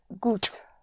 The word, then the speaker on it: gut: The gut is spelled G-U-T. The U always is pronounced like a double O in English, like in food.